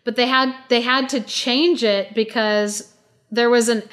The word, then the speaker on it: Chang'e: So it's spelled C-H-A-N-G apostrophe E, Chang'e.